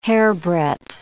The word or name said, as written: hairbreadth